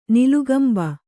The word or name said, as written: ನಿಲುಗಂಬ